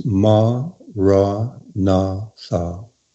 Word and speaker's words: maranatha: So if you take that word, you would say it as four syllables, ma, ra, na, tha.